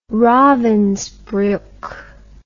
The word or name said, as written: ravensbruck